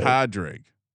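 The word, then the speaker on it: Pádraig: No, is it Padraig? That's not how you say it. – That's exactly how you say it. – Padraig. Not Padraig. Not Padraig. Bro, I'm from Boston. And my A sounds different. Padraig. What do you want from me? Padraig.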